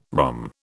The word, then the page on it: rhomb: British English